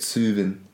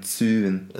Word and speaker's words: Tsuvian: That's how you say it, tsuvin. – Tsuvin. – T-S-U-V-I-A-N, tsuvin.